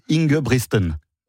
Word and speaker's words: Ingebrigtsen: Ingebrigtsen.